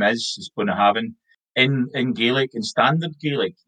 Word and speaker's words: Bunnahabhain: Our favourite, Bunnahabhain. – I think I tend to say it like Bunnahabhain. – Okay, yeah, you're both pretty close. So, Bunnahabhain's how I hear people saying it in English.